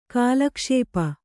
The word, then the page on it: ಕಾಲಕ್ಷೇಪಿ: kālakṣēpi